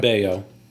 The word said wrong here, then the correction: Bayeux: I know how to pronounce the town Bayeux. – Okay, whatever. B-A-Y-E-U-X.